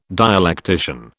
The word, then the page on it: dialectician: British English